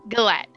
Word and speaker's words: galette: G-A-L-L-E-T-E. Galette. It's a butternut squash and caramelized onion. – Galette.